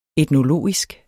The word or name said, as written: etnologisk